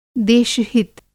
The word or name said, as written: देशहित